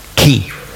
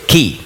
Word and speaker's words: Chi: Chi, das hat man eigentlich ausgesprochen, Chi. Nicht Schweizerdeutsch, Chi. Also nicht Chuchichastli, in dieser Art, sondern eben Chi.